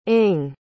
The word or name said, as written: ing